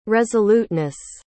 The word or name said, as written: resoluteness